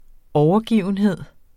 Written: overgivenhed

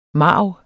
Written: marv

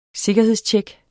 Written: sikkerhedstjek

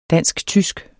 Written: dansk-tysk

